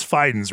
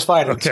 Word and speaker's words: Fidens: It is Fidens, right? – It is Fidens. – Okay, I pronounce it that way, and people sometimes correct me incorrectly. – Nope. – Okay, perfect. – You are correct.